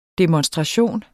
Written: demonstration

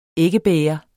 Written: æggebæger

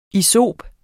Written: isop

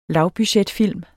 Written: lavbudgetfilm

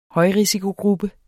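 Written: HØJRISIKOGRUPPE

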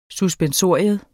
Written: suspensorium

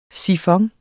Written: sifon

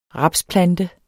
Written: rapsplante

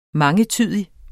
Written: mangetydig

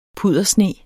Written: puddersne

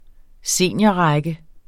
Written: seniorrække

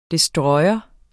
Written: destroyer

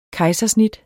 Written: kejsersnit

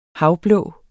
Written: havblå

